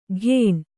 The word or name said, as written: ಕೇಣ